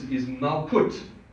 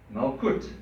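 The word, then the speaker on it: Malkut (מלכות): But the way you say it in Hebrew is Malkut, Malkut.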